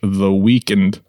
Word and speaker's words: The Weeknd: On the 16th, Abel Tesfaye, also known as the American recording artist The Weeknd, if I'm pronouncing that correctly, there's no E between the K and the N.